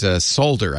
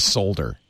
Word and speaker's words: solder: But solder, I can't get used to solder. It sounds like something's wrong. Solder. And I know if I do that all the Americans would say, why are you saying it wrong?